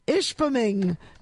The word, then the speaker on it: Ishpeming: It's located in Michigan in a place called Ishpeming, which is spelled exactly the way it sounds.